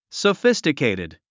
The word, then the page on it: sophisticated: səfístəkèitid; ソフィスティケイティド